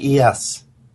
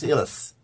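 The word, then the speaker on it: Illyes: He's Swiss. I can't believe that this escaped me. The guy is Swiss, but he's French-Swiss. It's Gary E.S. That's how you pronounce Ilitz. Gary E.S. There you go. Because nobody can pronounce it properly, he responds to Gary Ilitz. But the correct pronunciation is Gary E.S.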